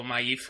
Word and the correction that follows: Maeve: que... maíz se llama la... Sí, tiene un nombre muy raro. Maeve, sí, Maeve. Sí, Maeve, lo he dicho bien.